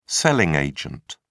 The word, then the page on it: selling agent: British English